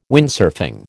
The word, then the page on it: Windsurfing: American English